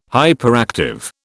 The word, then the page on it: hyperactive: British English